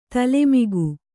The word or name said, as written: ತಲೆಮಗ